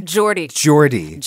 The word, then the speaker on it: Geordie: Am I saying it right? – Yeah. – Geordie.